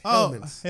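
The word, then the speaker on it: Hellmann's: I think you got the name wrong. – What? – I don't think it's Hellman's. – It is Hellman's. – I think it's Hellman's.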